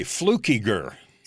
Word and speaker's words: Fluckiger: Fluckiger.